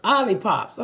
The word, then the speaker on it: Olipop: Olipops.